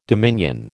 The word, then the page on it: dominion: American English